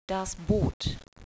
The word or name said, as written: Boot